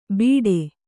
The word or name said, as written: ಬಿಟೆ